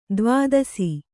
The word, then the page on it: ದ್ವಾದಶ: dvādaśa